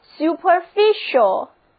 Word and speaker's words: superficial: 最后我们来接触一个同样讲表面的单词 superficial，字母 c 在 ial 之前发嘘的音，整个单词就读作 superficial，在表示脸面的词根 fic 之前加上表示在什么之上的 super 前缀，后面是 ial 的形容词后缀，整个单词连起来就表示表面的。